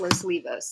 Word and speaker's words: Los Olivos: Or Los Olivos. It's pronounced Los Olivos, right? – It's pronounced Los Olivos, yeah. – I always do that wrong.